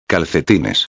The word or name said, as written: calcetines